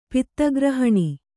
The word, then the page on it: ಪದಗ್ರಹಣ: pada grahaṇa